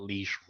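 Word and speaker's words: Lady Leshurr: It's like Lisa, but Leisure.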